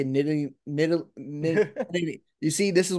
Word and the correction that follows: Nittany: Nittany Lions. – Nittany Lions.